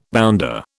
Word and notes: bounder: British English

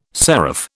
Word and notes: seraph: British English

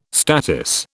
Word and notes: statice: British English

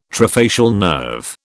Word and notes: trifacial nerve: British English

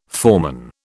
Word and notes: foreman: British English

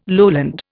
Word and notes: lowland: Indian

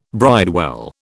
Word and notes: bridewell: British English

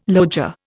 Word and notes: loggia: Indian

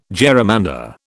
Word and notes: gerrymander: British English

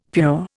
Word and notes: pure: Indian

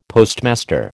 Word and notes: Postmaster: American English

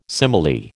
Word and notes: simile: American English

